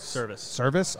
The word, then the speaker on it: Servais: Same with Scott Servais. – Service.